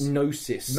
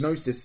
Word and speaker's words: Gnosis: It's Gnosis. – Gnosis Studios. – We've been getting it wrong the entire time. – Hey. – The G is silent.